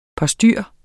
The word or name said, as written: postyr